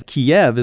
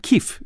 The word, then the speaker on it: Kyiv: It's because the citizens of Ukraine wanted to change its name because Kiev is what the Russians use. – Yes. – And Kyiv is what the Ukrainians have used. – Good for you. That's the pronunciation of the Ukrainians, but it's called the Russification when the Russians wanted it to be more Russian-sounding, so they gave it that pronunciation, Kiev, and it worked.